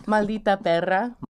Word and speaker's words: Maldita perra: Maldita perra. – Maldita perra. – Right. We gotta work on you rolling your R's.